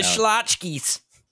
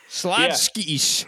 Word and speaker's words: Schlotzky's: Let's go down the Schlotzky's. – Yeah. Well, you didn't say it right, but yeah, that's good. Schlotzky's.